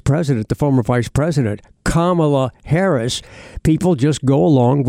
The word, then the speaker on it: Kamala: Her name is pronounced Kamala. Kamala, it's pronounced Kamala Harris.